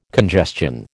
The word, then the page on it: congestion: American English